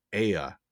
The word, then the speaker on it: Aia: The Curtis family is selected to test a new home device, a digital assistant called Aya. It's spelled AIA.